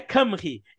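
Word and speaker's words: Cymru: Aquele C-Y-M-R-I-R-U se pronuncia Camry.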